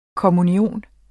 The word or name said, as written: kommunion